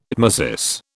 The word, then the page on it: tmesis: British English